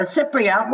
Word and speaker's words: Cypriot: You were born in Cyprus, and is it true you consider yourself, and I want to pronounce this right, a Cypriot or a Cypriot? – A Cypriot.